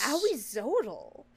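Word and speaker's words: Ahuizotl: This is Auizotl. Spelt very interestingly. It's A-H-U-I-Z-O-T-L.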